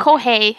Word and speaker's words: Kohei: So, so the manga currently is at 33 volumes and the author is Kohai Horikoshi. I think I said his name correctly. – Yes. Oh, okay. Kohei. I would say Kohei, Kohei Horikoshi. My bad.